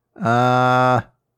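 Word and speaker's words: Araxá: Let's see. Hold on. Pronunciation. Alexa. It's something similar to that. – Arasha. – Arasha. Okay. That's what I heard.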